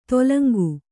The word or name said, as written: ತೇಲುಂಗ